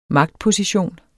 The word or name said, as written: magtposition